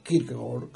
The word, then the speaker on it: Kierkegaard: En este post-scriptum, nos dice, de primeras, ya les he leído la definición con la que concluye, en este post-scriptum nos dice Søren Kierkegaard, o Kierkegaard, como se mejor pronuncia en danés,